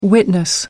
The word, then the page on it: witness: American English